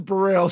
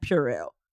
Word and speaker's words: Burrell: I will try not to pronounce it like Purell. – Purell. Purell. No, Purell. Burrell.